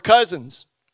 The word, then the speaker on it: cousin: They were cousins. Did I say that right? Usually my wife tells me that I add a T to the end of cousin. Cousin.